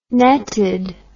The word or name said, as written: netted